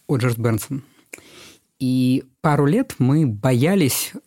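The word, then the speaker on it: Berndtson: Не Бернстон, не Бернстан, а Бернсон.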